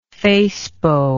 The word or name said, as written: facebow